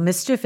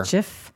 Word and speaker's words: MSCHF: And it was a company called Mischief, and it's spelled M-S-C-H-F. – Like a rapper. – Mischief. Kind of like that.